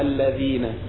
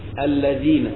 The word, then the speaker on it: الذين: وأيضا ننتبه لإخراج اللسان بالذال من قوله تعالى صراط الذين هذا الحرف الذال يخرج فيه اللسان فينطق هكذا الذين وليس الذين كما يحصل من كثير منا الذين، صراط الذين أنعمت الذين.